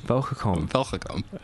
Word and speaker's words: Belgacom: Belgacom. – It's very, very good accent there.